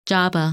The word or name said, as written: Jabbah